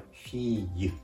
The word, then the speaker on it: fille: 発音自体はフィーユ、フィーユと、まあカタカナ発音でも良いんですけれども、実は綴りが FILLE L の発音を「ゆ」と発音するケースがフランス語ではしばしばあります